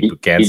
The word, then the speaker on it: Bugansky: We are here with Tom Bugansky. Is that, I'm not sure I've been pronouncing your last name correctly, Bugansky. – You did. You said it correctly.